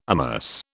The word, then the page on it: amerce: British English